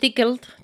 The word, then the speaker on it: Tickled: Y bueno, pues es Tickled, sería la pronunciación correcta.